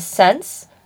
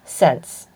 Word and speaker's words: Saens: That was Sense, but it's pronounced S-A-E-N-S, pronounced written, spelled. Oh, I'm having one of those moments. It is pronounced Sense, but spelled S-A-E-N-S.